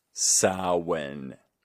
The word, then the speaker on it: Samhain: All right, kids, repeat after me. Samhain. Not Samhain. Samhain. That is how it is pronounced.